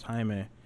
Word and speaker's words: Jaime: You know, and I've heard it pronounced just like my name is Jaime. People say Jamie, you know, it's just been my whole life.